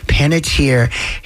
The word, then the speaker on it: Panettiere: Panettiere.